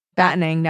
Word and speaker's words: battening: Oh, I've said I used to say battering down the hatches all the time. Now, occasionally, I'll think it and then go, wait, do I say it right? – Battening down the hatches.